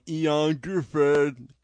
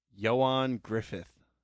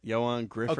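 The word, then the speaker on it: Ioan Gruffudd: You know, we always call him Ian Gifford because we don't know. So, Entertainment Weekly had one of them little box things. – Right. – And they had the phonetic pronunciation of his name. – And it is? – Johan Griffith. – Wait, what? – Isn't it? Johan Griffith.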